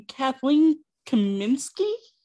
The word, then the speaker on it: Kathleen Kaminski: Today, Snoops and Sleuths, I have with me Kathleen Kaminski.